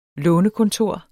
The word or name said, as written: lånekontor